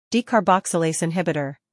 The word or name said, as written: Decarboxylase Inhibitor